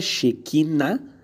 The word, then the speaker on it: Shekinah: A primeira é Shekinah, com acento agudo no último A.